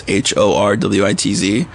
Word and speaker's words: Horwitz: It's Horwitz. No second O. Not necessary.